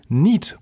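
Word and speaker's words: knead: für den englischen Begriff gesagt no Brot. Und das ist natürlich falsch. Danke, lieber Andrea. Richtig heißt es nämlich No Need Brot. Ja, also das englische Kneten wird Need ausgesprochen, genauso wie brauchen.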